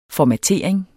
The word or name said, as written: formatering